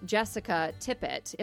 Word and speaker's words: Jessica Tippett: So Jessica Tippett, am I saying that correctly as well? – You are, yep. Just like tip it over like a piece of paper.